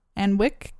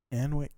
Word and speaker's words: Alnwick: Yep, and it's located in the UK next to the Anwick Castle. – Anwick Castle. – I might be pronouncing that wrong. There's a weird L in there. I don't know about... – Can you spell it? – A-L-N-W-I-C-K.